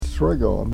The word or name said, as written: sregon